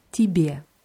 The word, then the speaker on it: тебе: Tibet wird mit zwei Jes geschrieben, aber das erste Je wird eher wie I ausgesprochen wieder. Tibet.